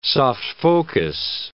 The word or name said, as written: soft-focus